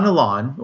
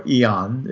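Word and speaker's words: Illán: Don Ilan, or Ian, if you give it the true Spanish pronunciation or Castellano pronunciation,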